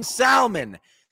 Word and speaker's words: salmon: There's an L in salmon. Salmon. Almond. Egg. Say it right.